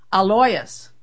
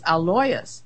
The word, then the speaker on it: Alois: His father's name is pronounced Alois, which is easier to say, really. You know, when you get realize it, I've been calling it Alois, and then Alois, but it's Alois, Alois.